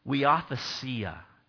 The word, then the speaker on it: huiothesia: He uses the Greek word huiothesia. It's huiothesia.